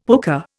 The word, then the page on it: pooka: Indian